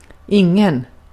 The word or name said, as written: ingen